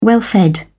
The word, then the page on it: well-fed: British English